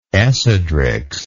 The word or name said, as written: Esidrix